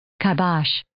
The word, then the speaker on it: kibosh: So Merriam-Webster is saying kai in both of their, kibosh. Kibosh. – Yeah, so they're unclear about where the accent goes on the first or second syllable, but kibosh. They're saying kibosh, yeah.